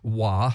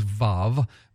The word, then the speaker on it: vav: And so that one letter, it sometimes is pronounced wa, sometimes vav.